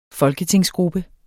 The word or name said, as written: folketingsgruppe